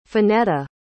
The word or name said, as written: fanetta